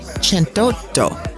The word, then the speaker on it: centotto: For episode 108, which is pronounced cento e cento, or as the Italians love to drop a vowel or two randomly, is pronounced episodio centotto.